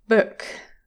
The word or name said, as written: book